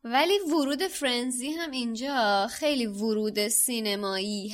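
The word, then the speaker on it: Firenze: یه جوریه که آدم احساس میکنه باید فایر بخونه، ولی در اصل اسمش فرنزیه که شما تو کتاب صوتی هم گوش بدی همین فرنزی خونده میشه.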